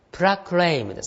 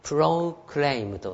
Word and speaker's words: proclaim: はい、まあ、このようにね、ちょっと文法解析してありますけども、石垣島 is keen to proclaim the fact、はい、石垣島は事実をプラクレイムですね。これはプラクレイム、あるいはプロクレイムと、ね、両二つの発音の仕方があります。プラクレイムという発音の仕方と、プロクレイムというね、発音の仕方、二つあります。